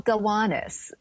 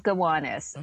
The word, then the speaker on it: Gowanus: It's called Gowanus. I know. It's called Gowanus.